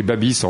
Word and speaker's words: Babiš: Babiš, ça s'écrit Babiš en français et ça se dit Babiš en tchèque.